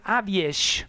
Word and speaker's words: AWS: La banda se deia AWS, que se pronuncia Aviesh, aquesta formació de metal i post-hardcore d'Hongria que havien debutat en el 2006 amb aquest títol Vislat Nihar.